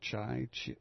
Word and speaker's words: Chi: As editors at Closer did last week, I think it's pronounced Chai, C-H-I, Chi, executives defended publishing the photos despite the furor from London.